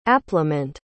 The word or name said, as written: appliment